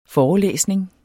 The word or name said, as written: forelæsning